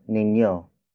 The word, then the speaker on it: ninyo: Lastly, we have ninyo. Ninyo. Ninyo. Ninyo is spelled as N. I-N-Y-O.